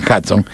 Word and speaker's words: Hudson: Bueno, se escribe Hudson, los gringos dicen Hudson.